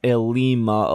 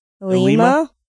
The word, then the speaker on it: Ilima: So I think that's our goal, except we might talk about Ilima a little bit. – Okay. – Is that how you say it? – Ilima? – Ilima? – That's how I say it.